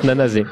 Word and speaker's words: Nanase: Non, non, mais ça s'écrit comme ça, mais ça, se prononce Nanase.